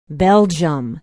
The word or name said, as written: belgium